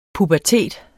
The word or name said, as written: pubertet